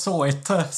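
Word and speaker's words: Sawhit: Eh, jag, jag, tror att det ska uttalas Soit.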